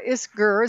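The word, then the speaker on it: Isgur: And then he says, Sarah Isker, is that how you say it? – Yeah.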